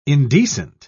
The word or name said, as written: indecent